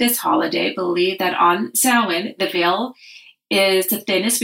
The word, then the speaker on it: Samhain: The people will read it S-A-M and be like, what's Samhain? It's actually pronounced Samhain.